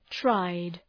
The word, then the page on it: tried: traıd